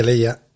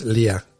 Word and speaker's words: Leia: En es como, como, como Leia, aquí se dice Leia y en inglés, no sé si no en italiano, es Lia. Lia.